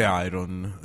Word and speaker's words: iron: Sempre iron. Perché okay, tutto iron. – È tutto iron. Iron, tra l'altro, la R non si pronuncia, eh? – Iron.